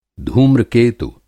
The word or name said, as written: धूम्रकेतु